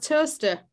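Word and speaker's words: Towcester: Mind you, there's one not far from you now that's pronounced Toaster, but is spelt Towchester.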